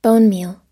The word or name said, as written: bonemeal